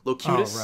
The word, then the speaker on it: Locutus: Locutus.